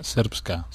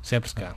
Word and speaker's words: Srpska: Srpska. – Srpska.